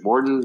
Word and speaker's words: Morten: Is it Morten? Martin? – It's Swedish. So I think it's, we say Morten.